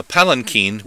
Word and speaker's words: palanquin: As we saw with verse 7, a palanquin, which kind of rhymes with Martin Sheen, is a covered chair or litter on poles, a sedan chair carried by four or more bearers.